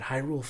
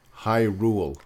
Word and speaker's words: Hyrule: But Hyrule fucking Warriors, man. – Spell it. Spell the first part. No, don't hum. – H-Y-R... U-L-E Warriors. – Hyrule. – Hyrule.